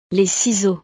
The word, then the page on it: les ciseaux: frances